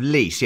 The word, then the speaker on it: Lys: It's the Tears of Lies. – That's what I do. I drink. And I know things. – Nice. – The Tears of Lys. Yes, I will accept that. Just get your pronunciation correct, please.